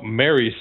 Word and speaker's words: Merrye: And he explains about Mary Syndrome, which is spelled M-E-R-R-Y-E for no reason? – The extra E at the end is silent.